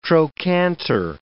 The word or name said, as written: trochanter